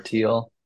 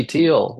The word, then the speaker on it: Thiel: I mean, technically, there's 2 ways to pronounce it. You can do Thiel or Teal. And for most of my life, my family pronounced it Thiel. And a couple of years ago, my dad and my sister were like, it's actually Teal. We're going to pronounce it Teal now.